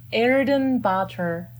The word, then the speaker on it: Erdenebaatar: Way wrong, right? – Yeah, right. It's, almost. – No, it's not. Okay, go ahead. Say, please. – It's Shuteen. Shuteen Erdenebaatar.